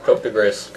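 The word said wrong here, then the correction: coup de grâce: Cope de grace. – Coup de grace. – Coup de grace, yeah.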